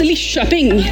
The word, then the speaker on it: Lidköping: Jag jobbar fortfarande på att uttala det utan att kränka halva slätta. Jag tror att det uttalas Lidköping. Ish.